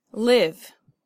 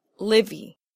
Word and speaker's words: live: Live. Again, in the word live, the E is silent. It's not livy.